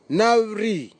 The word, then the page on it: navri: Nafe; Tanna